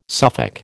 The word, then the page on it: Suffolk: American English